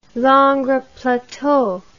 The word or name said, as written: Langres Plateau